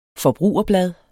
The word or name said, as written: forbrugerblad